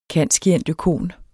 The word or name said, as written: cand.scient.oecon.